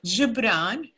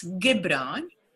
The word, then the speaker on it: Gibran: Yes, let me pronounce to you his name, Gibran, not Gibran, and I'll tell you why. And it's Khalil, not Kahlil, and I'll tell you why. So Gibran, Khalil Gibran, that's his name.